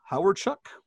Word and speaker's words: Hawerchuk: We have Robert Trump, Kamala, the wrestler, Carol Hardy, Dale Hawerchuk.